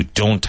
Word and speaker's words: don't: Now, make sure you pronounce the T in don't. You don't have my support.